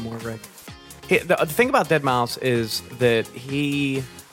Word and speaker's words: Deadmau5: How about we just fucking get straight to the point, and I'm going to butcher their name, but it is Deadmau5, right? – Yeah. Yeah. It's Deadmau5.